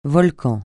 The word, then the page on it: volcan: francais